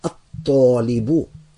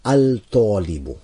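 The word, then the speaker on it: at-talibu (الطالب): I would say at-talibu, the student. It would be totally wrong for me to say al-talibu.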